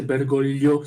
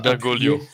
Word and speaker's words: Bergoglio: Et comme nombre d'Argentins, il est des origines italiennes, donc le G, on le prononce pas, c'est Bergoglio, c'est un L. – Bergoglio, ok.